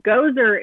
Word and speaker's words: Gosar: Gosar is how I've been told his name is pronounced, as if he is that creepy dude in the Ghostbusters movie. Spelled differently, pronounced the same.